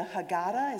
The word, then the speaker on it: Haggadah: I was once at a Jewish black seder, one of my many seders, and I hope I don't say this wrong, but in the Haggadah, is that right? – Yeah.